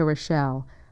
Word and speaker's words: Rochelle: Now to get VOA's current program schedule, write to me, Rita Rochelle. My name is spelled R-I-T-A. Rochelle is R-O-C-H-E-L-L-E.